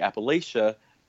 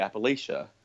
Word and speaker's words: Appalachia: So when, and it's not wrong to say Appalachia, because the people in the Appalachian Mountains into Pennsylvania and Vermont and Maine, they do pronounce it Appalachia. So their pronunciation is right for them. But when you're talking Southern Appalachia, it's more proper to use that pronunciation of Appalachia.